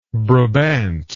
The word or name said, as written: brabantine